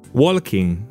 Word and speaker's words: walking: Ah, this is a pronunciation issue. Walking. Don't say that L. You don't need to. Walking. Walking. Similar ones. Talking. No L pronounced in it. It's written, yes. You write the L. The consonant is there, but not pronounced.